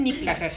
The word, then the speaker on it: Mictlán: Según yo la pronunciación correcta es Mictlán.